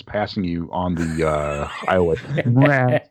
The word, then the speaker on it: Mraz: I don't know how you pronounce his surname. Go on, Ben, you're more hip than me. No? – Mraz.